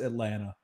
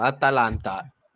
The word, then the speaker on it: Atalanta: So next, so Wednesday, Atalanta, which I honestly just pronounce Atlanta at this point. – A lot of times, ATL, Atalanta. – I'm not adding that extra fucking syllable in there.